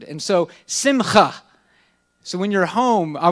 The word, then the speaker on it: simcha: And this word was simcha. It's spelled S-I-M-C-H-A.